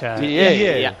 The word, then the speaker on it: Ié: Además con acento en la E, Ié,